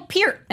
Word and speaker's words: Peart: I didn't know that, like, and just because of name pronunciations, I was calling Neil Peart my entire life until he died, and someone was like, Neil Peart, and I was like, what the hell are you talking about? – But only Rush nerds say it that way.